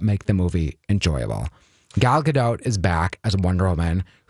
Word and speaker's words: Gadot: Oh, Gal Gadot. – Great. You do pronounce the T, but it is Gadot.